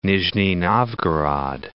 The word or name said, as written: Nizhni Novgorod